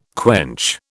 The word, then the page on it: quench: British English